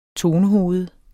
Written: tonehoved

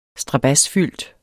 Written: strabadsfyldt

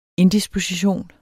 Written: indisposition

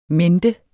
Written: mente

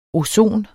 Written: ozonen

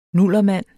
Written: nullermand

